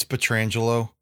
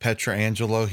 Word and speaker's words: Pietrangelo: I know I've been picking on the announcers for this game, but uh, it's Petrangelo. It's not Petrangelo. He has said it several times. I know he had a relative in the early 80s that played hockey and he went, pronounced it Petrangelo but Alex pronounces it Petrangelo.